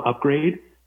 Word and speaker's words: Upgraid: You know, it's spelled AID, so UPGRAID, so Upgrade.